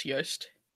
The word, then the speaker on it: Yoste: Is it Yoste? Yeah, I'm pretty sure it's Yoste.